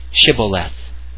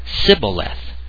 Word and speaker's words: Shibboleth: And when he said, 'No,' they said to him, 'Then say Shibboleth.' And he said, 'Sibboleth,' for he could not pronounce it.